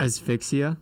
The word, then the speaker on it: asphyxia: Asphyxia.